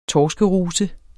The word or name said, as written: torskeruse